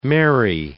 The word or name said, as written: merry